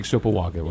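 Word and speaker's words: Sopoaga: Yeah, the two strongest guys and two of the stronger guys, probably the strongest guys in the National Football League is Sopawaga. Did I say that right? – Isaac Sopawaga, right.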